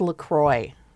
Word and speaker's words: LaCroix: It's pronounced LaCroix because it's from Wisconsin.